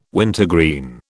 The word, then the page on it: wintergreen: British English